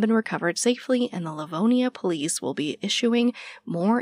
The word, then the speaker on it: Livonia: Livonia. – Livonia.